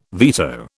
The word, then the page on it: veto: British English